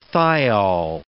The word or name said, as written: thiol